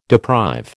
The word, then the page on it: deprive: American English